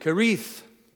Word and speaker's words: Cherith: A modern Hebrew speaker says it's the brook Cherith. And then I get on Strong's Hebrew Concordance, and they pronounce it Kerith. I don't know how it's pronounced, but there it is. We'll just say, we'll give it the soft CH.